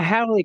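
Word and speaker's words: haole: Note, and I hope I'm going to say this right, AG, and correct me if anyone knows, is it a Howley girl? – You got it. – A Howley girl.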